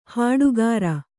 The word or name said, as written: ಹಟ್ಟಿಕಾರ